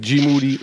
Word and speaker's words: Moody: I just have to say, I have to stay on my toes with G Moody, last name rhymes with Duty, who predicted at the beginning of the season that the Boston Celtics would not be in the playoffs.